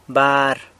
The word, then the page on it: bar: San Lucas Quiaviní